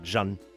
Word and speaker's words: Szun: And Sun is spelled S-Z-U-N, so it's more like Jun.